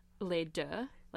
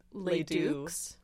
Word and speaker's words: Les Deux: Les Deux. Like, okay. – Both. – But, yeah. Like, but obviously no one speaks French. – So they would always yeah. pronounce it Les Dukes.